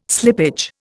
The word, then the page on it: slippage: Indian